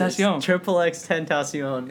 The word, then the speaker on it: XXXTentacion: No, it is. XXXTentacion. That's how he pronounces it.